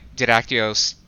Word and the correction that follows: Didactylos: It's Didactylos, as in... – Didactylos. – Two dactyls, as in two fingers, because he's flipping the world off.